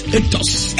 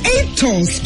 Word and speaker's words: Aetos: A-E-T-O-S. Etos. – Etos, correct.